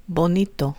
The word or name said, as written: bonito